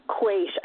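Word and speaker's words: quaich: I think it's a quaish, which it's a Gaelic word, so it's probably spelled, you know, like S-O-M-E or something. – Right. – Ridiculous, but it's pronounced quaish. I'm pretty sure that's how it's pronounced.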